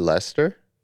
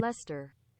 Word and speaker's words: Leicester: Is it Leicester? – I think so.